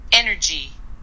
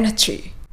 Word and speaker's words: energy: energy はい、 energy できましたか唇をアヒル口にして発音すると日本語のエナジーというさらっとした響きから英語のエンチー尖った響きに変わってきます